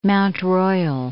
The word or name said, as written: Mount Royal